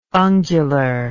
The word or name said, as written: ungular